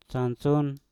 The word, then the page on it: sansun: mpur